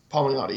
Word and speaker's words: Palmiotti: Palmiotti, yeah.